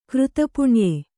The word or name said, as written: ಕೃತಪುಣ್ಯೆ